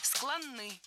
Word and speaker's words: склонны: То есть мы в состоянии паники, в состоянии спешки значительно более склонны к необдуманным поступкам. – Склонны. Неверно. Правильный вариант – склонны. Ударение в этом кратком прилагательном такое же, что и в полном. Склонны, а не склонны.